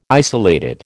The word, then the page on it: isolated: American English